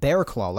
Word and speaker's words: Barraclough: And it's interesting because when you first look at this guy's name, you think it's pronounced like something along the lines of Bearclaw. Barraclough or Barraclough. It's Barraclough, but it turns out it's pronounced like Barraclough, like a bear's claw.